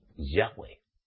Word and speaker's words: Yahweh: Porque el verdadero nombre de nuestro Elohim viviente y creador es Yahweh. Yahweh. Cristianos, ¿no es así? ¿Hay alguna diferencia? Preguntadla. Vamos a ver los que decís YHWH. ¿Por qué lo pronunciáis? Si la orden es que demos a conocer el nombre de Yahweh. Si tendríais verdaderamente el Espíritu de Yahweh, el propio Espíritu de Yahweh, que es el consejero, el consolador, os diría cómo se pronuncia.